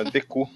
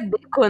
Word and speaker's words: Deku: Deku. isso é muito errado, né, velho? Deco Tree. É, a pronúncia é Deco, né? É Deco.